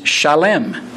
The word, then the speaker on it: Shalem: The Hebrew word is Shalem, S-H-A-L-E-M, with the slight adjustment of just one vowel sound.